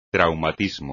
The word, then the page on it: traumatismo: espagnol